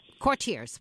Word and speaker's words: Courtiers: Am I pronouncing it correctly? Is it Courtiers? – I think it's Courtiers. – Courtiers, with an R. Okay, Courtiers.